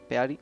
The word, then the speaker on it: Peary: Edwin Peary, me imagino que se pronuncia, Peary. – Sí, bueno, pues telegrafió...